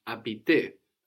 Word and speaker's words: habiter: To live somewhere, to live somewhere is habiter, habiter. – Habiter. – And this is H-A-B-I-T-E-R. So we have a silent H, habiter, like the English word habitat.